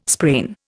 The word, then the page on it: sprain: Indian